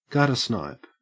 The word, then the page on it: guttersnipe: Australian English